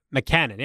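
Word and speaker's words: Mackanin: I'm going to look up your pronunciation. We're going to find it. It is McCannon. Yeah, I said it right.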